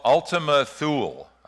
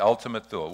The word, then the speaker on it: Ultima Thule: It's called Ultima Thule. I believe that's how you pronounce it, T-H-U-L-E, Ultima Thule.